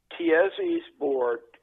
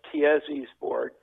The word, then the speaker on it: Tiezzi's Board: Tiezzi's Board, T-I-E-Z-Z-I, apostrophe S, Tiezzi's Board.